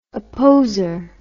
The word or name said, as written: opposer